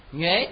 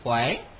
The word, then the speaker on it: nhuế: Cái chữ này cũng có, các vị cũng có thể đọc là chữ khỏe ha, à, cũng là chữ nhuế, chữ khỏe, à, nhưng mà chúng ta quen nhuế rồi ha.